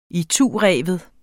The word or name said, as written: itureven